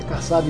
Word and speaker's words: Kassab: Agora eu tô falando certo, é Kassab mesmo.